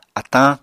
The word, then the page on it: atañ: Apatani